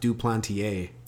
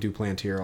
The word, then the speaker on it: Duplantier: I will say, his name is pronounced Duplantier. – Duplantier. Okay, that's one I had to get clarification on. Okay, everyone was calling him Duplantier.